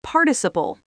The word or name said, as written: participle